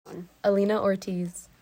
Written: Alina Ortiz

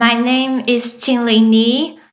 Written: Qingling Ni

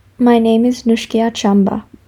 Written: Nushkia Chamba

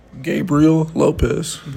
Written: Gabriel Lopez

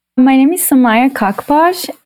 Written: Somayeh Khakpash

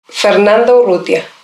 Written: Fernanda Urrutia

